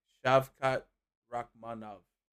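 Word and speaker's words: Shavkat Rakhmonov: I will say it properly. Shavkat Rachmanov.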